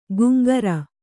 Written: ಗುಂಗರ

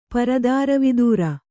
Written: ಪರದಾರವಿದೂರ

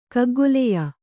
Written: ಕಗ್ಗೊಲೆಯ

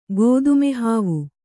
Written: ಗೋದುಮೆಹಾವು

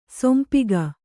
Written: ಶಂಪಾಕ

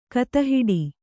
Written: ಗೋತಿಹೊಡೆ

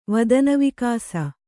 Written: ವದನವಿಕಾಸ

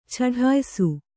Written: ಚಡಾಯಿಸು

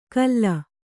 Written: ಕಲ್ಲ

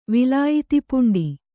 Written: ವಿಲಾಯಿತಿಪುಂಡಿ

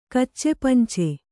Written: ಕಚ್ಚೆಪಂಚೆ

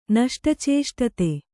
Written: ನಷ್ಟಚೇಷ್ಟತೆ

